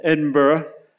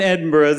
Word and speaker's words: Edinburgh: They think possibly it came from Scotland, Edinburgh. And in Edinburgh, there was a well. – You didn't say that right. It's Edinburgh.